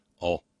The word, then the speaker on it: oil: Now, of course, if you want to talk about a place that worries a lot about all, and it's best, it's barely even a syllable when properly pronounced in Southernese, all. It's more of a grunt, all. O-I-L, all.